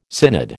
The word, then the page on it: synod: American English